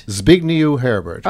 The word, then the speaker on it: Zbigniew Herbert: Do you know how to pronounce that, Herbert? Zbigniew Herbert. – Zbigniew Herbert. – Oh, I got it pretty close.